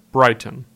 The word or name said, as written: Brighton